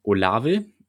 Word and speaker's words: Olave: Ich gehe davon aus, dass Olave, so spricht man den Namen richtig aus, ja.